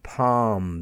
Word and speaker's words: palm: Okay, and then before the letter M, we also have the silent L, bam, B-A-L-M, calm, C-A-L-M, the calm weather. And palm, the palm of your hand, P-A-L-M.